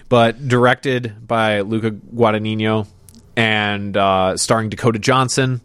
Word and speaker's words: Guadagnino: This one, directed by, and I'm going to butcher the name here, Luca Guadagnino. – Guadagnino, yeah. – Guadalupe. – Luca Guadagnino.